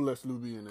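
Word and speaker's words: Les Nubians: Yeah, L-E-S Nubian? – Les Nubian. Okay, it's SWV because I don't know who Les Nubian is. So, SWV, guess what you want. – You don't pronounce the S. It's French.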